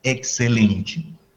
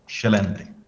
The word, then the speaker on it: excelente: Esa misma palabra que en portugués de Brasil pues pronunciamos como excelente, un portugués diría como excelente.